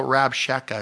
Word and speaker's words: Rabshakeh: They're just, you know, Rabshakeh is how you say his name.